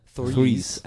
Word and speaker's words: Threes: Trees, das war jetzt sehr irisch ausgesprochen. Threes. Ein richtiges TH.